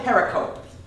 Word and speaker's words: pericope: I'm writing on the That pericope doesn't exist in any of the Dead Sea manuscripts. – What's the word you're using? – Pericope.